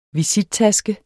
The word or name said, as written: VISITTASKE